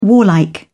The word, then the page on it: warlike: British English